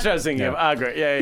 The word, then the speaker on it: Agra: what's your favorite part of going to Bagra? You know? – And well, it's Agra, actually. – Agra, whatever. – The B is silent. – Yeah, the B, no B.